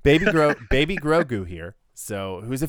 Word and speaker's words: Grogu: Who's this Gorgu? Am I saying it wrong again? Grogu.